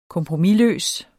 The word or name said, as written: kompromisløs